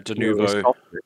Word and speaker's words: Denuvo: Denuvo, how do you pronounce it? – Yeah, Denuvo.